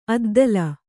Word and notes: ಅದ್ದೇಲಿ: addēli

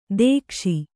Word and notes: ದಕ್ಷ: dakṣa

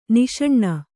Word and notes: ನಿಶಾನೆ: niśane

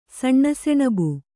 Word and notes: ಸಣ್ಣಸೆಣಬು: saṇṇa seṇabu

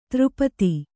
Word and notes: ಧಾರಾಪಾತ: dhārā pāta

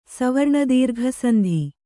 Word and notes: ಸವರ್ಣದೀರ್ಘಸಂಧಿ: savarṇa dīrgha sandhi

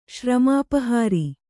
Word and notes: ಶ್ರಮಾಪಹಾರಿ: kannada

